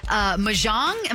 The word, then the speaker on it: Mahjong: Mahjong, am I pronouncing that right? – Yep. – Mahjong, I think.